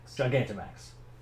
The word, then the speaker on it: Gigantamax: And then we also go through the new Gigantamax, how do you say it? – Gigantamax.